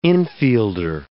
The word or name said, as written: infielder